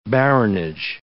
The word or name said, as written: baronage